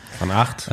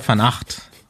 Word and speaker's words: Van Aert: Van Aert, würde ich sagen, spricht man das aus, oder? – Van Aert, okay.